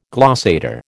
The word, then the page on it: glossator: American English